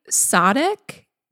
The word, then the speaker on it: Sadeq: So there are a lot of pronunciations of Sadeq. That seemed correct to me, like the emphasis on the sa.